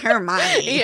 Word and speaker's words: Hermione: I've been saying it wrong this whole time. – Really? Because I was like, he's saying it wrong. – Yeah. You were there. – It's Hermione.